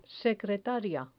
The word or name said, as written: secretaria